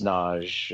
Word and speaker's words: Nagy: And my last name is, don't worry about it, but it's pronounced Naj. It's spelled N-A-G-Y, which is hard to find.